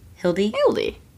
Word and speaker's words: Hildie: I don't want to say that wrong. – Let me look. – H-I-L-D-I-E. Hilde.